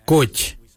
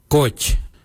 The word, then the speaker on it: goodj: Karli is spelled G-I-I-L-Y-I, karli. For the traditional stone axe, we say gotch, gotch. Gotch is spelled G-O-O-D-J, gotch.